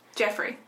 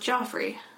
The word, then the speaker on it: Geoffrey: You know the name like G-E-O-F-F-R-E-Y? – Jeffrey. – Yeah. But sometimes pronounced Joffrey.